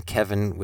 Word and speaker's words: Kevin: Remember, my website is spelled, my name is spelled like the number 7, Kevin with 2 E's, no I.